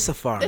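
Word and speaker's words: Safaree: No, it is Safari. – Oh, it is Safari. – It's just spelled weird. – Oh, okay. – That's where I got Safari.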